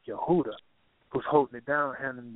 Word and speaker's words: Yehuda: There are no J's in the Hebrew or the Greek. They're pronounced as Y. So the tribe of Judah is really pronounced Yehuda, and that's what his name is.